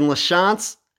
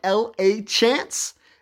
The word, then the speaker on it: LaChance: Personal Facebook is Brandon LaChance. My name is spelled like L-A Chance.